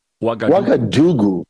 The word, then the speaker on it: Ouagadougou: Oh, Ouagadougou. – Yeah, and you spell it O-U. It starts with an O-U.